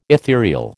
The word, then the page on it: etherial: American English